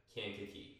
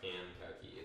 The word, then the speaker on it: Kankakee: Kankakee. – There you go. – Kankakee.